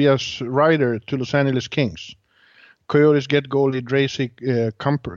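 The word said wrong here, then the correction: Darcy Kuemper: Hur uttalar man det då? – Darcy, Darcy Camper.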